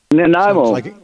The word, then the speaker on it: Nanaimo: We've got a call from, now, I don't know if I can pronounce this right, Nanaimo, British Columbia? – Nanaimo.